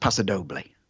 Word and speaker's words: paso doble: And yeah, I forget the spelling because I'm dyslexic, but it's pronounced paso doble. So yeah, paso is correct, Hope.